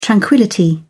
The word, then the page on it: tranquillity: British English